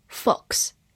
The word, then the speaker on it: fox: Fox, F-O-X, fox. 字母 o 发短音 o，x 发 x，fox。美式发音字母 o 口型比较大，fox 也是可以的。